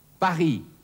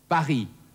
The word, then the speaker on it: Paris: Und drittens, das stumme S, zum Beispiel im Falle der Stadt Paris. Auf Französisch Paris. Paris.